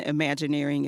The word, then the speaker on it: Imagineering: imagineering, as you said. And am I saying that right? – Yes. – Imagineering, yes. As you said.